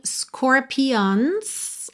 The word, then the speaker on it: scorpions: Aqui fica um pouquinho scorpions, ok? Mas a sílaba tônica é logo a primeira, score, score, ok?